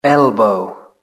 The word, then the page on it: elbow: British English